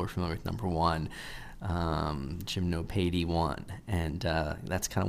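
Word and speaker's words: Gymnopédie: He said, hey man, you know, I like the Seed AI interpretation of Gymnopedie. And actually, it's probably pronounced Gymnopedie,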